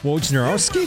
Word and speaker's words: Wojnarowski: Wojnarowski.